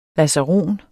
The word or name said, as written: lazaron